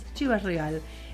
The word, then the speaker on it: Chivas Regal: Chivas Regal.